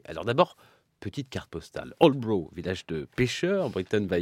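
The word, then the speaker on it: Aldeburgh: On dit Aldebourg. Repeat after me, Emily.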